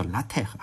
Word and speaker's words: LaTeX: 讲派的时候我突然想起来了，上一期我不是讲那个 LaTeX，我读的是 LaTeX 哈，有人就专门纠正我这个发音嘛，说这个错了，叫 LaTeX，还发了个语音给我。